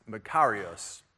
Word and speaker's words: makarios: There is this Greek word that appears in the New Testament that is pronounced Makarios.